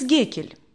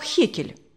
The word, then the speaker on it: Геккель: Эмс Геккель. Иногда его фамилию произносят как Хекель. Да, просто разница в произношении, улыбнулся Алексей Александрович.